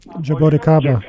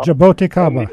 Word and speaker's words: Jaboticaba: And what's that other tree we have that Jacacaboba? – Jaboticaba. – Jacacaboba? I'm not saying it right, but you know what I'm talking about. – Yeah, Jaboticaba.